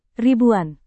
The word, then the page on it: ribuan: indonesian